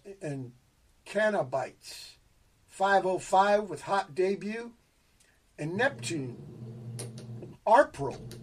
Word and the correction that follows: RPRII: Did I pronounce that right? No. – Ripper, I think that might be Ripper 2. – Oh, it's Ripper 2. – Yeah. Okay. Yeah, RPRII.